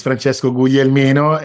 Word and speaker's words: Francesco Guglielmino: It's Francesco Guglielmino.